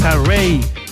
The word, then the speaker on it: Raye: Array. Se escribe Raye, R-A-Y-E, con esta canción que se llama I Know You're Hurting.